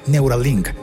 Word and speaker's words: Neuralink: También le pertenece Neural Inc. No, perdón, lo dije mal. Neuralink. En el siglo XXI de hoy ya habíamos hecho un episodio contando la historia de Neuralink.